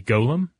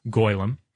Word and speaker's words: golem: It can also, since this is a Jewish idea, it can be pronounced goylem. – Mm-hmm. – which is, I guess, more in keeping with the Hebrew.